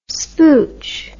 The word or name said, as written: spooch